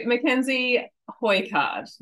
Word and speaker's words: Mackenzie Hoycard: So Mackenzie Hoycard, did I say that right? – Yes.